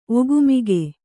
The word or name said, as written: ಒಗುಮಿಗು